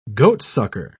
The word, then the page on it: goatsucker: British English